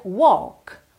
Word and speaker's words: walk: Atenção que essa palavra walk não tem walk, não tem esse L de verdade, tá bem? Então você lê W-A-L-K, mas você pronuncia walk, walk.